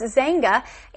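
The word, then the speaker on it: Xanga: Now, you see that word Zanga sounds like it should be spelled with a Z at the beginning, but it has an X, and that is how it is pronounced, Zanga.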